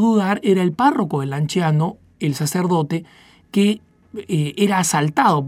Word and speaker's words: Lanciano: que había sido testigo del milagro eucarístico del Lanciano, o Lanciano, si lo pronunciamos en su original en italiano,